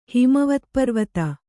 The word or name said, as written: ಹಿಮವತ್ಪರ್ವತ